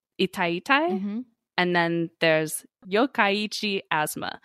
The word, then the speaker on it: Yokkaichi: In last episode, I pronounced it Yokkaichi, because I am really bad with Japanese pronunciation, but I did the how do you pronounce video. – Yeah. – It's pronounced Yokkaichi.